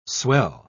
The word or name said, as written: swell